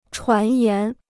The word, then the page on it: 传言: chuán yán